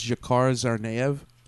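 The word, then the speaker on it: Dzhokhar Tsarnaev: I believe his name is pronounced Jakar Zarnaev.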